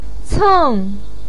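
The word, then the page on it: ceng4: tone 4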